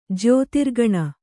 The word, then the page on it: ಜ್ಯೋತಿರ್ಗಣ: Kannada